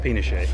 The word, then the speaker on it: Pinochet: Panache is a friend of mine, actually. – We don't want it to be nice guy. Actually pronounced Pinochet. – General panache. – General Pinochet.